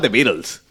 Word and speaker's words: The Beatles: Aquí en pero pronúncialo como lo pronunciaste. – La banda se llama The Beatles.